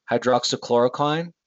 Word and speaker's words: hydroxychloroquine: I can't remember how you say it. It's hydroxychloroquine. Is that how you say it? – Yeah.